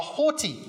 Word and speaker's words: haughty: But, you know, many of us struggle with this because we are haughty. I love saying that word, particularly with a British accent. I think it sounds better. Haughty, right? You have to pronounce the T.